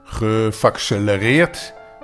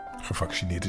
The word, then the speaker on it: gevaccineerd: Gevaccelereerd? Dat geloof ik niet. Gevaccineerd is best een moeilijk woord. Maar de papa van Dick Tromp kon het toch niet echt goed uitspreken. Gevaccelereerd. Het is gevaccineerd.